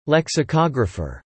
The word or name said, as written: lexicographer